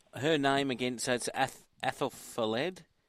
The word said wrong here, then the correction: Ethelfled: How do I say her name properly? – Ethelfled, I think it's called. – Ethelfled. Yeah, that's the Saxon Ethelfled.